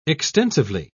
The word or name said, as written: extensively